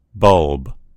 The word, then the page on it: bulb: American English